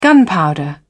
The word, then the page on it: gunpowder: British English